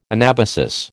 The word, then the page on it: anabasis: American English